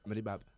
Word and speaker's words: Brave Up: ¿Cómo se dice? Up. – Brave Up.